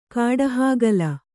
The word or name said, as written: ಕಾಡಹಾಗಲ